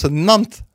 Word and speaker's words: Nantes: Alors en Aveyron, on prononce toutes les lettres, mais là, pour une fois, on prononce Nantes. Tu vois, il y a la subtilité. – Okay. – On prononce pas le E. Okay. Quand on prononce Nantes, teux, c'est Nantes en Bretagne.